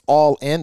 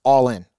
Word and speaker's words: Ollin: And it's All In. That's how it's pronounced. Even though it's spelled OLLIN, it's All In, okay?